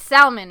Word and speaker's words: salmon: No, people say salmon. Like, that's the right way to say it. – What? They don't say salmon. It with the L? – No, because the L is silent, which is...